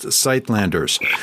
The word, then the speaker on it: Suidlanders: spelled Suidlanders. It looks like Suidlanders, but it's pronounced Sightlanders.